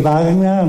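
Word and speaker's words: Bagna: O sea, nosotros, más que baña, voy a hacer una corrección: baña, vamos a usar baña, porque gen en italiano es pronuncia ñ, para hacer una buena pronunciación, que no haya otros que dicen están hablando mal.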